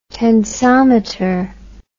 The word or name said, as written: tensometer